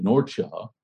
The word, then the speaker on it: Norcia: There once lived in the city of Norcia, yes, that's the appropriate way of pronouncing it. Someone who speaks Italian told me and corrected me. I usually get corrected for saying it Norcia instead of Nursia, but so just trust me on this.